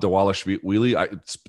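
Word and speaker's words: Dvalishvili: Mirab Dawalashvili. Spelled Dawalashvili. I'm sure that the V is pronounced like a W.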